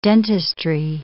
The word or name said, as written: dentistry